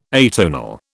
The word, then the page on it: atonal: British English